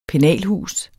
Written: pennalhus

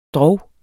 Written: drog

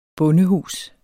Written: bondehus

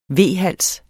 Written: V-hals

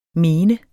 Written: mente

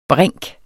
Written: brink

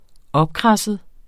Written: opkradset

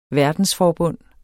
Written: verdensforbund

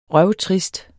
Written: røvtrist

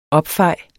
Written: opfej

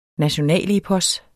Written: nationalepos

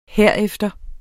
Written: herefter

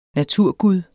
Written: naturgud

